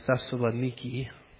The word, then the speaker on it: Thessaloniki: In fact, I was just in this area, Corinth, Greece, Thessalonica, that they call over there Thessaloniki, which is always bothersome when you've mispronounced a word your whole life and taught it publicly.